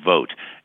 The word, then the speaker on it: Vogt: is William Vogt. And it's spelled V O G T, but it's spelled like, you know, go out and vote.